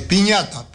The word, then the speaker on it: Pinata: Y Cardoso, Pinata, David Pinata, es Piñata, pero Piñata se dice, pero es Pinata se escribe.